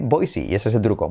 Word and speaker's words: Boise: ¿Cómo se pronuncia Boise? Se pronuncia Boise. Boise, sí, claro, claro que voy, Boise.